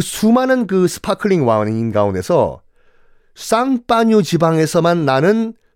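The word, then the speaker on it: Champagne: 그러니까 샴페인을 프랑스어 발음으로 하면 쌍파뉴거든요.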